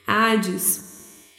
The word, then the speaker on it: Hades: Hades, pronúncia meio inglesada, nada a ver. Hades.